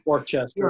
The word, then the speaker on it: Worcester: And so my mom took me to the big city of Worcester, Massachusetts, which is not a big city, but it's spelled very differently than you would think. – Yeah. – Worcester.